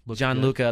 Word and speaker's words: Gianluca: Giolanco Busio was playing. – Gianluca, that's a fine. Dollar in the jar. Gianluca Busio.